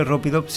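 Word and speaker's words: Ropeadope: Se escribe Ropidop, se pronuncia así, Ropidop.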